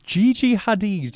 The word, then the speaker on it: Gigi Hadid: Apparently her close friend, Gigi Hadid. – Yeah, right? – I believe you're pronouncing that correctly.